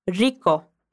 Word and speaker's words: rico: Quando você ouvir de novo o áudio dos nativos, eu quero que você repare como a Lola pronuncia esse R no início da palavra. É algo como rico, rico.